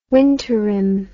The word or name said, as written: winterim